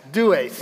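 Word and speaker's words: Deus: And he said, "There's no such thing as a hard V in Latin. Instead, it would be pronounced dues.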